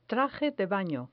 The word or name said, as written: traje de bano